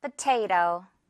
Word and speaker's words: potato: 먼저 첫 번째 단어는 감자인데요. 포 아니고 퍼. 가볍게. 자, 듣고 바로 따라해주세요. Potato.